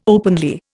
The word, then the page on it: openly: Indian